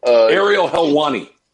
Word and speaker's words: Ariel Helwani: But MMA expert Ariel, well, is that how you say his name? – Ariel Helwani. – Helwani, he actually handicapped a fight.